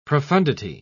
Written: profundity

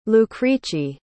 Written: lucrece

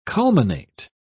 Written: culminate

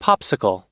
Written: popsicle

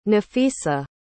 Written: nafisa

